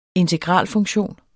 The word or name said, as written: integralfunktion